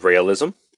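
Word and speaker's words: Raëlism: So there's something called realism, is how I'm going to pronounce it. R-A umlaut E-L-I-S-M. And it was founded in 1974 by Claude Vorilon, who would call himself Rael, R-A-E-L.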